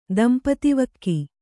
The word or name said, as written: ದಂಪತಿವಕ್ಕಿ